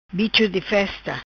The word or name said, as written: Bicho Di Festa